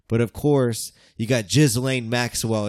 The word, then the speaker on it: Ghislaine: It's Ghislaine. – It's Ghislaine.